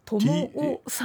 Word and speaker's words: TOMOO: これは何と発音したらいいんですか。 – トモオさんですね。 – トモオ TOMOO ですよね、はい、そうです。